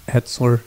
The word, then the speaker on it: Hetsler: It's a story of a Jacksonville financial advisor, Robert Hetsler.